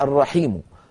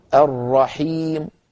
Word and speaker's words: الرحيم: مثلا الرحيم، الرحيم، عفوا الرحيم، الرحيم، أسكن الميم ثم أضم الشفتين.